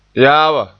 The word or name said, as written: yaaba